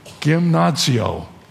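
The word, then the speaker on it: gymnazio (γυμνάζω): Comes from a Greek word, and here's the Greek word, I'm going to pronounce it, gymnazio. It's the word from which we get the word gymnasium, almost exactly right out of the Greek language.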